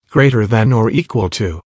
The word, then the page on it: greater than or equal to: American English